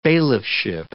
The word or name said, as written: bailiffship